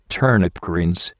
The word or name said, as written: turnip greens